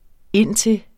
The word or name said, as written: indtil